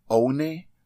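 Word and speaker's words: Oné: You know, you already know that it's spelled like just like the number one, O-N-E, with a little accent over the E. Ever since I moved to the mainland, I've been called a lot of things. Oni, One, and of course, One. But you already know that it's pronounced One.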